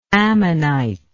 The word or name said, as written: ammonitish